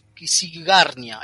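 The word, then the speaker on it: Księgarnia: Es un juego del año 2019 del autor Jog Kung, de la editorial Natsa Kisierna. A ver si lo dije bien. Kisigarnia. Ahí está.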